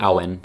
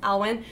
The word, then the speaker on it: Alwyn: Alwyn. – Alwyn.